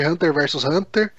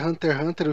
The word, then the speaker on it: Hunter x Hunter: Hunter x Hunter, é, que algumas pessoas devem chamar de Hunter versus Hunter. Uh, eu acho que a pronúncia é Hunter x Hunter, o X está lá só para estilizar,